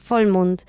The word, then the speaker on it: Vollmond: ¿La B Vollmond se pronuncia como F, no? – Sí, la B se pronuncia como F, Vollmond.